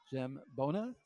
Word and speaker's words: Zambogna: And we want to thank the person that called in and let us know that it's Ricky Zambogna. The G is silent.